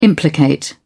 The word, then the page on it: implicate: British English